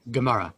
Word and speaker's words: Gemara: Now, so, Mayor, just for my learning purposes, there's also, as part of the Talmud, I don't know how to say it, Gemara? – Yeah, that's right. Yeah, Gemara. – How do you say that? – Gemara.